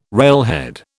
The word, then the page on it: railhead: British English